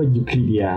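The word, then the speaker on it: Euclidea: ยูคลิเดีย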